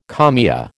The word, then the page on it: kalmia: American English